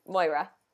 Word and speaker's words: Moira: Moira, did I say that right? – Moira.